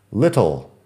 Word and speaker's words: little: Little. Bueno, acabo de decirlo de una forma poco natural porque estoy intentando pronunciar bien para el podcast, pero realmente en inglés americano la T es más suave.